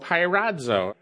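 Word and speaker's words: pyrazo (πειράζω): that's the word in Greek, it's pronounced pyrazo.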